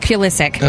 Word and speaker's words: Pulisic: A lot of people seem to think that she is in a relationship with soccer star Christian Pulisic. I said it right all morning, and now I forget how to say Pulisic. – It. Pulisic.